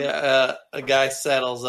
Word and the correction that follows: sidle: up next to you. Sidle? What? How do you say that? – My love. Yeah, sidle. – Saddle. – No, sidle up to somebody. – Sidle? – Yeah, S-I-D-L-E.